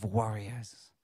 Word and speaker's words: warrior: But the Lord says, I want to raise up a generation of warriors. Not worry with a long I, warrior with a I-O-R, okay? Warrior.